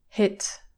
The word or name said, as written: hit